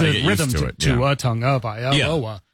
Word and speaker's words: Tagovailoa: And he goes, Tagovailoa? – He's like, nope. Tongue.